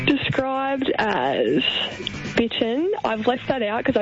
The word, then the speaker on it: Bittern: You've said it perfectly. Yes. He's from Bitten. – I wasn't sure if it was Bittern, Bitten.